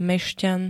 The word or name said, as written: mešťan